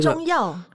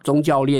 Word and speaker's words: 宗教: 那部电影我记得他有扯到了工程围标啊，还有官商勾结啊，收银牙吞啊，中药敛财。 – 中、哦那个、中药宗教敛财，宗教对对对对，发音不标准。